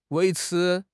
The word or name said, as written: 维持